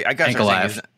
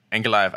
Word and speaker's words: Ankalaev: Yeah, I like how do you say his name? Magomed. I got Ankalaev. – Ankalaev. I gotta start remembering his name, because he's gonna be a name that we're gonna see at the top of cards pretty soon.